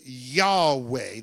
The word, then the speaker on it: Yahweh: And so Y-H-V-H or Y-H-W-H became Yahweh. That's how they would say it.